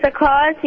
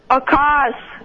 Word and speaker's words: Akaash: Hi, my name is Akash. The answer is Rolls Royce. – What's your name? – Akash. – How do you spell it? – A-K-A-A-S-H.